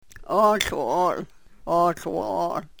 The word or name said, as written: oohl wo-ol